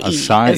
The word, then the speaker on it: acai: Acai is how you say it.